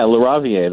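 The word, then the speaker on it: LaRaviere: It's Laravia? I have a hard time with his name. – Yeah, Laravia, that's the pronunciation.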